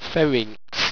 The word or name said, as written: ferinx